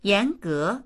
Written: yan2ge2

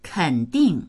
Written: ken3ding4

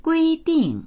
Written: gui1ding4

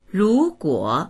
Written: ru2guo3

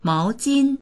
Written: mao2jin1

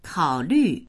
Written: kao3lv4

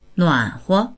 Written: nuan3huo

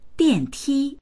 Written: dian4ti1

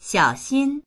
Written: xiao3xin1

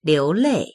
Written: liu2lei4

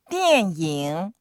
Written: dian4ying3

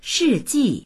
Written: shi4ji4

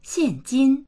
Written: xian4jin1

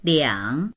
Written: liang3

